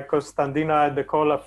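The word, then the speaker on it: Costantino Iadecola: Did you you attended the mechanisms of neurovascular dysfunction and interaction with AD, uh, AD pathology, uh, which uh, Costanino Led- Iadecola led, um, and I think salt was discussed in there as I butchered that name. – Yes, exactly. It was a really fascinating talk by Costandina Iadecola from Cornell University in the United States, and he actually he's been doing this kind of work for several years.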